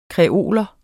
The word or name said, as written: kreol